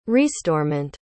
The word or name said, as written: restorement